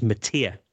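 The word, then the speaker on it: Matija: He pronounces it as Matia, just saying now.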